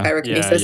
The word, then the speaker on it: pyrokinesis: Well, I think that's interesting because Lung has the ability to, for pyrokinesis, is that, am I saying that correctly? – Yeah, pyrokinesis? – Yeah.